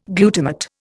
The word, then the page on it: glutamate: Indian